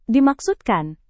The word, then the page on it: dimaksudkan: indonesian